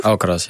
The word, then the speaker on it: Alcaraz: Kan dat ook wel eens, want je staat ook wel eens tegenover Alcaraz. Nou, zeg ik het goed? – Alcaraz, ja.